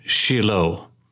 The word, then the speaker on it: Shiloh: It is a fascinating story about a great miracle of God that took place at ancient Shiloh, called by the Jewish people Shiloh. Often we use a long I sound because that is a characteristic of our English palate, but it is very unusual for there to be a long I in Hebrew. Everything, for instance, is more of what we would call a short I.